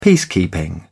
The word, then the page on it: peacekeeping: British English